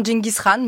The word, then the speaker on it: Gengis Khan: C'est vrai que l'évocation même de Gengis Khan, ou Gengis Khan, vous prononcez comment? – Moi, je dis souvent Genghis Khan, mais Gengis Khan.